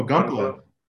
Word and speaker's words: Gunpla: Gunpla, is that how you pronounce it? Gunpla? – Oh, Gunpla.